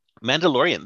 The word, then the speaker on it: Mandalorian: Well, it continued I'm never going to say it right. The Maladorian. – Mandalorian. They actually Mandalorian is not bad, because that was and I've heard that's really good.